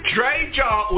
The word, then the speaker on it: Dre JA: Before we continue on, did I pronounce that correctly? Is it Dre Jar or is it Dre J-A? – Dre J-A, that's it. – Dre J-A, okay, so it's not Jar, it's Dre J-A.